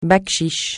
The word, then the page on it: bakchich: francais